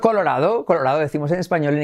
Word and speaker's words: Colorado: a Colorado. Y la D como R suave, semiclave, Colorado. Puedes decir Colorado, pero nadie lo dice así, es Colorado. Incluso lo dicen como una sílaba, Colorado, Colorado, Colorado.